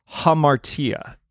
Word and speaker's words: hamartia: Today's Greek word is hamartia. You got to get that H sound at the beginning. Hamartia.